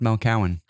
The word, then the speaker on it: Mel Cohen: My name's actually pronounced Mel Cowan. – Is that true? – It's true. – Have I been saying it wrong forever? – I actually don't care because Cohen would actually be better because Cowan sounds like cow.